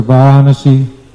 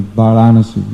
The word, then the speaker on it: Varanasi: Kashi or Banaras or Varanasi, they're all the same. Banaras is actually the modern pronunciation of Varanasi. You can hear how they sound almost the same. Varanasi, Banaras. So it's also called Kashi.